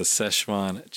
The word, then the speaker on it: Szechuan: Yeah, it's a Szechuan chicken tender.